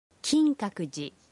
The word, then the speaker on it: 金閣寺: The pronunciation is Kinkakuji. Kinkakuji.